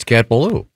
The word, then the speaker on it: Cat Ballou: It's Cat Ballou. – No, that doesn't have anything to do with Boogaloo. – It rhymes with Boogaloo.